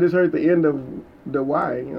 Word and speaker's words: Dawai: his name is Dewey, which is weird because it's spelled D-A-W-A-I.